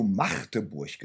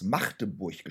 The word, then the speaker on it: Magdeburg: Hast du Magdeburg gesagt? – Ja, wie heißen die denn? – Nein, das ist richtig. – Magdeburg. – Nein, jeder Bewohner dieser Stadt legt Wert darauf, dass es Magdeburg gesprochen wird und nicht Magdeburg oder so.